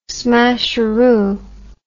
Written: smasheroo

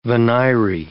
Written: venire

